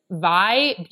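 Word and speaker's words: Vie: The brand is Vi Beauty and it's V-I-E Beauty spelled with an I at the end.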